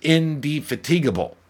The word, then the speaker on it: indefatigable: And this is the reason I like this word is because I've said it wrong for so long, and Dana had to correct me because the word is indefatigable.